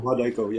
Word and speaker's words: Wadaiko: Now, it's Wadaiko. Am I saying that right? Wadaiko? – Wadaiko, yep.